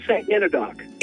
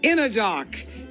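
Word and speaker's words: St Enodoc: Yeah, St. Enadoc. – Enadoc.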